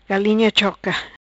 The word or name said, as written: Galinha Choca